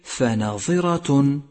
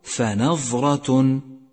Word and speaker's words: فناظرة: سابعا، احذر من اللحن في كلمة فناظرة، فناظرة، فالظاء مكسورة وليست ساكنة، فنطقها الصحيح فناظرة، ومن الخطأ أن تقول فنظرة، والفرق بينهما شاسع.